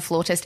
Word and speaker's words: flautist: I was actually incredibly impressed just then, A, with your pronunciation of flautist, and B, the fact that you knew that.